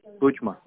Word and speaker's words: Тотьма: Тотьма, да. – Это Вологодский район, Вологодская область, поэтому все горят на О. Тотьма, вот так вот надо правильно говорить. – Угу. Тотьма, я запомню.